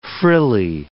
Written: frilly

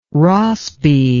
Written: Rossby

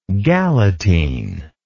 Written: galatine

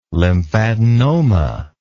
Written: lymphadenoma

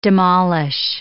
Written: demolish

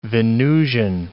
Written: Venusian

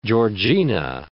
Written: Georgina

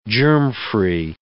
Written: germfree

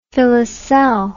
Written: filoselle

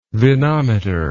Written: vinometer

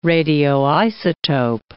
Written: radioisotope

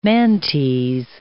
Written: Mantes

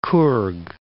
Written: Kurg

